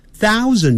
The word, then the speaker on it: thousand: Thousand，你看你有 th 开头，所以舌尖要吐出来啊，然后上齿下齿咬住。 Thou，ou 念成 ow，thousand，thousand，thousand，那个的不要念成 thousand 的，那个的要念出来的时候，你把它切住 thousand。